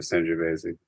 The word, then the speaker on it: Sangiovese: But I will correct Jeff and all the mistakes that he makes, including the word Sangiovese has an E on the end. You pronounce the E on the end of Sangiovese.